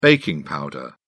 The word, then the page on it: baking powder: British English